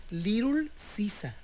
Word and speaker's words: Little Caesars: Que dicen Little Sisa. – Ah, como la... – Así como lo pronunciamos.